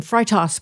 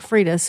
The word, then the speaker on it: Freitas: With Nick, I always want to say the German pronunciation, Freitas, but that's not the way he says it. I think it's Nick Freitas, who's running for Congress in Virginia, did a great job.